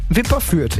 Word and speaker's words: Wipperfürth: Wahlführer aus Wipperfürth, habe ich richtig gesagt, Wipperfürth. – Wipperfürth.